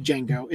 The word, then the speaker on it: Django: In the scene where Jamie Foxx is telling that guy the G is silent, the D in Django is silent, and the guy goes, "I know." That was a nod to the fact that guy played Django in the original movie.